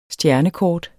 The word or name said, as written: stjernekort